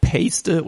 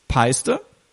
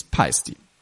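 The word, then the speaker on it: Paiste: aber Pasty oder Paste wird es nicht ausgesprochen. Auf Deutsch ist es Peiste und auf Englisch ist es Peisty.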